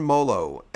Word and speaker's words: Mollo: John Mollo, M-O-L-L-O, 86 years old.